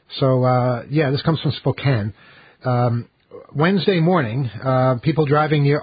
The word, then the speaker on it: Spokane: Spokane, right? – Yeah. – It's spelled Spokane, but you're not supposed to say it that way. You're supposed to say Spokane.